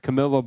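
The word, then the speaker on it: Camellia: Oh, I was pronouncing it like it was Spanish. My bad. Camilla Bowl as Georgia Southern takes on Buffalo.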